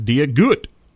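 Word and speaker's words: Dia duit: Dia Dut, which is Irish for hello, pronounced Dia Gut.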